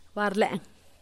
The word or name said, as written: barlen